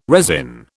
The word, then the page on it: resin: British English